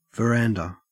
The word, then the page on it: verandah: Australian English